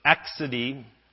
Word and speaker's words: accidie: Today we're going to cover the last two, which are the sins of accidie. How many remember that word? I didn't. Accidie, and that is how you pronounce it, which is often now called sloth.